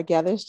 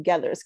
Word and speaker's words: Gethers: First, my name is Laura Gethers. Just like you said, thank you so much for pronouncing Gethers correctly.